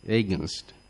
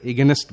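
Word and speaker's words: against: এগেসনের সে বিরুদ্ধে বা বিপরীতে এইগেনস্ট, আমরা এগেনস্ট বলবো না, আমরা উচ্চারণটা হবে এইগেনস্ট, এইগেনস্ট।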